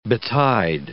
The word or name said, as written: betide